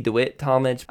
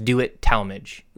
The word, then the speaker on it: DeWitt Talmage: DeWitt Talmage, is another one of those famous preachers from the late 1800s.